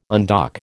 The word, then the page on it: undock: American English